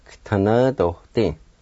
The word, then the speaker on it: GVTANVDODI: The V in the first part is a silent. You just pronounce the G sound like this. G, ta, na, do, ding. G, ta, na, do, ding. G, ta, na, do, ding. You kind of start the G sound with the T like this.